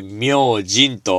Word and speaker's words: 明神: 明神って何と思った方たくさんいらっしゃると思うんですが、字で書くとですね、名前の名という字ですね。えー、それに神様と書いて、明神と読むわけでございます。